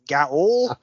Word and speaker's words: gaol: G-A-O-L, and how do you pronounce it, Gaol? – Gaol, it took me a while to realize that was just the British spelling, the only British spelling of jail, which we spell J-A-I-L here in the States.